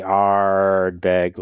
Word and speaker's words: Ardbeg: If I was going to pronounce it, I would say Ardbeg, like a pirate.